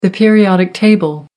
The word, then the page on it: periodic table: American English